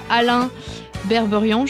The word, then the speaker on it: Alain Berberian: C'est un film réalisé par Alain Berberian, je le dis bien ou pas Oui.